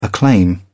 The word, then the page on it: acclaim: British English